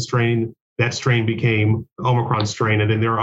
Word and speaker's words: Omicron: Is it pronounced Omicron? – Omicron, yeah. Yeah.